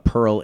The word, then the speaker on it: Perl: Pearl is, however, it is a homophone. I believe it's spelled P-E-R-L, but yeah, I'll give it to you.